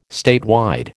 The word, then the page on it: statewide: American English